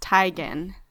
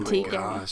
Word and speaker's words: Teigen: I read on Twitter that it's actually pronounced Teigen, not Teigen.